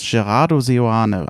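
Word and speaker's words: Gerardo Seoane: Der, so jetzt kommt, wie spricht, ich, ich glaube, er spricht sich ganz einfach aus, Gerard Corrado Seoane, also tatsächlich so, wie er sich schreibt.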